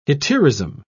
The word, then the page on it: hetaerism: British English